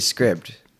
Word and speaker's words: Scribd: It's definitely Scribd because there's no E.